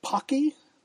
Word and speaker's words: Paqui: It's called Pocky. They spell it P-A-Q-U-I, and they say on the box that it sounds like hockey, so Pocky Hockey.